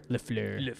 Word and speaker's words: LaFleur: I can never say Matt LaFleur because I always want to say it like Ben Stiller. LaFleur.